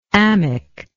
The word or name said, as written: amic